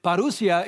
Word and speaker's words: Parousia: It's pronounced, or it's spelled P-A-R-O-U-S-I-A. Parousia.